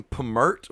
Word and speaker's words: Pmurt: and she calls him Pmurt, which is Trump backwards, and she says it rhymes with hurt, and that's what Trump's going to do to the country.